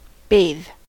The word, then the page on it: bathe: American English